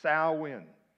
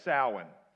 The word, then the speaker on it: Samhain: The original name of the festival is Samhain. Everybody say Samhain. Samhain. Now, it doesn't look at all like that's how you would pronounce it. But Samhain. And basically, it's an old Celtic or Gaelic word.